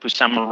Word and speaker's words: Buscema: It is the Englehart Busima, or how do you say his name? Buscema.